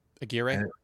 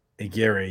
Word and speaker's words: Aguirre: I mean, how do I say his name? Nick Aguirre. – Aguirre, yeah.